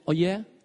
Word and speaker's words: œillères: Je prononce bien œillères? Ok, merci.